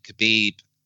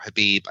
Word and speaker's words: Khabib: And during the fight, Khabib is saying, or Habib, I think is the right way to pronounce it, is saying to McGregor, let's talk, let's talk.